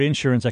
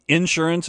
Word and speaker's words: insurance: Because you put the emphasis on the first syllable as opposed to the second one. – I do in insurance instead of insurance.